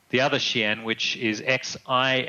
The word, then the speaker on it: Xi'an: Yeah, Xi is west and on is like safety. Okay. So yeah, it's Xi'an is west safe place.